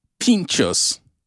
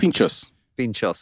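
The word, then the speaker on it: pintxos: Pinchos. – Correct. – Pinchos. – So the X has... I think about this all the time. I think about pinchos and I don't know where the X comes in to play at all.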